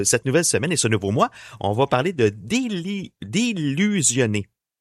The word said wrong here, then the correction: désillusionner: Désillusionner. – Il me semblait qu'il manquait une syllabe là-dedans.